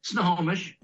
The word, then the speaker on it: Snohomish: Snohomish.